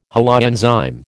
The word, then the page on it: holoenzyme: American English